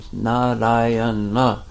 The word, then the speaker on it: Narayana: name, Narayana, four syllables, and he was calling his son.